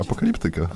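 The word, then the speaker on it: Apocalyptica: Ja miałem pewne takie skojarzenia też z apokaliptyką, apokaliptyką, apokaliptyką, apokaliptyką. Apokaliptyką, tak. Tyką. A tak się powinno wymawiać? Apokaliptyka. Apokaliptyka. No.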